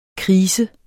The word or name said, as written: krise